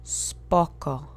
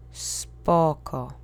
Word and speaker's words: spoko: The accent is on the first syllable. Make sure you keep those vowels monosyllabic. Let's repeat together three times. Spoko. Spoko. Spoko.